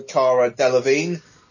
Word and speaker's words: Cara Delevingne: They are assembled by Viola Davis's Amanda Waller to combat a threat played by, I also forgot about her, Cara Delevingne. If that is how you pronounce her name. – It is.